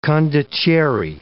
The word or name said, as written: condottieri